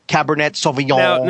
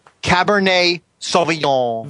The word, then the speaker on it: Cabernet Sauvignon: He's right about the French pronunciation, but it's Cabernet. You're going to drop the E-T. – Oh. Now, try saying that, please. – Cabernet Sauvignon.